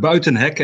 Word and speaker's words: Buitenhek: It's Mark Buitenhek, actually, but you're more than close.